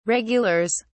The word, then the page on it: regulars: British English